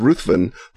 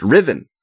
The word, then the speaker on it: Ruthven: And I had the glorious experience at Dragon Con of being corrected in my pronunciation of Lord Ruthven by none other than Chelsea Quinn Yarbrough, who says it's pronounced Riven.